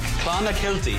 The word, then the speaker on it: Clonakilty: Clonakilty.